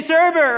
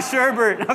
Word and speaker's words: sherbet: It's not Sherbert. Okay, I've been saying like, there's no R after the E.